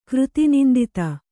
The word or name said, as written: ಕೃತಿನಿಂದಿತ